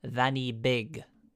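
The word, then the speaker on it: Fan y Big: the mountain, which is pronounced Vanny Big.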